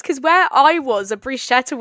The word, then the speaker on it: bruschetta: Oh wait, actually, I'm going to talk about this starter I had in Venice, bruschetta.